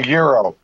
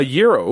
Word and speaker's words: gyro: I'm eating a gyro. – A gyro? Ooh, and pronounced it correctly.